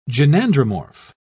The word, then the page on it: gynandromorph: British English